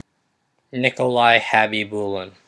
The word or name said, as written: Nikolai Khabibulin